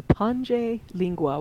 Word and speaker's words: Pange Lingua: Yes, it's the Pange Lingua, which means sing my tongue.